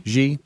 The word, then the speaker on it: Xi: Xi, is that how you say his name? Xi? – Xi.